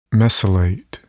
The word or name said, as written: mesylate